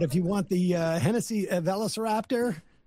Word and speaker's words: Velociraptor: Two lucky winners will get to choose between, I can't pronounce these things, a Lamborghini Huracan Sturato or a Ford Hennessy Velociraptor. – Velociraptor, which is a, thank you, which is a souped-up Ford Bronco, or $100,000 in cash.